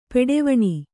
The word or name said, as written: ಪಟ್ಟವಣೆ